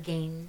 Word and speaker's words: Gein: And then Ed Gein or Gain, how did you say it? – I usually pronounce it Ed Gein.